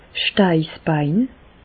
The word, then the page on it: 's Steissbein: Alsatian; Bas-Rhin; Reichshoffen